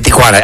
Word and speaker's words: Tijuana: Se va arriba por dos puntos los tucanes de Tucana. ¿No? ¿Cómo es? ¿Los tijuanes de Tijuana? No, los tucanes de Tijuana. Ahí está, ya lo dije bien.